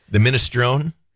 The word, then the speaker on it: minestrone: And they had a minstroni soup that was fabulous. Yeah. What I could afford. – the minestrone? – Minestrone. Yes, I can't pronounce things.